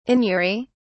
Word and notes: anury: British English

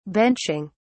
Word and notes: benching: British English

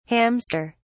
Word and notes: hamster(s): British English

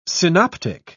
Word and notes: synoptic: British English

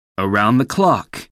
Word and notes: around-the-clock: American English

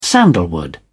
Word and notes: sandalwood: British English

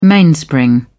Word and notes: mainspring: British English

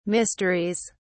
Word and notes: mysteries: British English

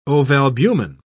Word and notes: ovalbumin: British English

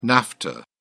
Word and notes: nafta: British English

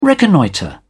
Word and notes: reconnoitre: British English

